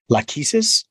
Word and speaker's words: Lachesis: It's Lachesis, right? So did I get it? – Yeah.